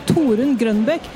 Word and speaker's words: Torunn Grønbekk: Only thing is, like, if you're trying to say my name like the Norwegians would, okay, it would be Torunn Grønbekk, but no one, like, that's not a thing. – Yes. – Like, no one says that.